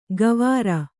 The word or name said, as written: ಗವಾರ